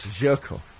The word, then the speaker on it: Zhirkov: Zhirkov.